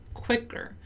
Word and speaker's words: quicker: Let's take 1 second here to talk about the pronunciation of the word quicker. Just as happens in the word miss, the letter I doesn't have the same sound as the letter I in Portuguese, right?